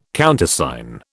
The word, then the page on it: Countersign: British English